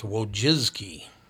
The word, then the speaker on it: Wojcicki: Oh, her name is actually pronounced Wojcicki.